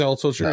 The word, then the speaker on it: Altucher: James Altucher I can never get his name right. Altucher, right? – Close. Yeah, James Altucher, yeah.